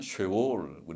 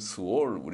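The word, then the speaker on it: 쉐올 (שאול): 악인이 죽은 후에 그 영혼이 들어갈 지옥, 그것을 구약 표현으로는 쉐올, 우리는 스올, 우리 성경에는 스올, 그리고 히브리 말로는 쉐올, 쉐자를 짧게 발음하는 쉐와 그러는데 쉐올, 스올에 가까운 쉐올, 그리고 헬라어로는 하데스라고 하는 하데스는 그게 지옥을 가리킵니다.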